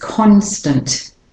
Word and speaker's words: constant: You can hear the o clearly in con. So this is a stressed syllable. And the a in tant becomes the schwa. So it's not tant, but tant. Constant. Stunt. Or tint. So I don't say constant, it's constant.